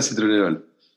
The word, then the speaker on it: citronelol: citronelol, sí. Beta citronelol. Perdón, lo dije mal.